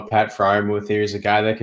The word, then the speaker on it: Pat Freiermuth: Pat Freiermuth. Did I say that right? – Pat Freiermuth, yep.